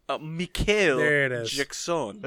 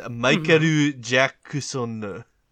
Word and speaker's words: Michael Jackson: I don't think you're pronouncing that right. – Mikael Jackson. – It's Michael Jackson.